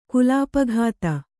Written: ಕಲ್ಪಕತೆ

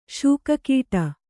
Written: ಶೂಕಕೀಟ

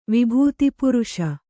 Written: ವಿಭೂತಿಪುರುಷ

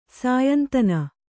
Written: ಸ್ಯಂದನ

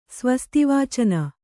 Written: ಸ್ವಸ್ತಿವಾಚನ

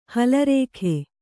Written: ಹಲರೇಖೆ

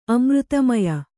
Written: ಅಮೃತಮಯ

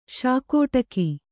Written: ಶಾಕೋಟಕಿ

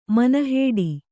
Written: ಮಣ್ಣುಹಿಡಿ

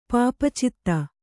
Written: ಪಾಪಚಿತ್ತ

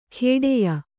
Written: ಖೇಡೆಯ

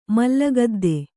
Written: ಮಲ್ಲಗದ್ದೆ

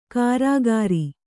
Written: ಕಾರಾಗಾರಿ

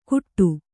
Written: ಗುಟ್ಟು

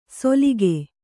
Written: ಸೊಲಿಗೆ

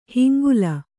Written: ಹಿಂಗುಲ

